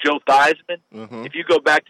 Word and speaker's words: Theismann: everyone will give you a blank look because the real family name is Theismann. – Oh. – They changed it to Theismann at Notre Dame to rhyme with Heisman, and they were trying to get the Heisman Trophy.